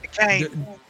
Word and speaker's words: Duquesne: I don't know how to say this team's name, but how do you say that? – Duquesne. – Is it Duquesne? Duquesne? What a English language is weird. English language is weird, bro. It's Duquesne. Are you seriously? There's an S in there.